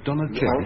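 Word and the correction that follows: Donizetti: No, put it down phonetically for us. – Steve will know. Donny Chetty. Does that strike a chord at all? – Give or take it. Yes, Donny Chetty.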